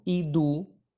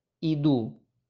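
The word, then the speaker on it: idu (ἰδού): En cambio, me llama profundamente la atención que después de decir, la túnica blanca resplandeciente, aparece una palabra que la voy a pronunciar en griego, idu, idu.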